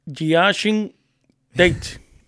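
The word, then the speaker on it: Jae'Sean Tate: Que es, estoy hablando de Giashin Tate. Así es que se pronuncia, papá del equipo.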